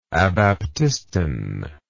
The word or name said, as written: abaptiston